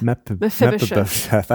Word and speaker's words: Mephibosheth: Can you spell it for us? – Mephibosheth. – Mephibosheth. – I think, you nailed it. I think you 100%.